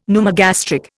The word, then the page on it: pneumogastric: Indian